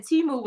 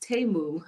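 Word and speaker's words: Temu: So it's I did find out that Timu was actually pronounced Tamu.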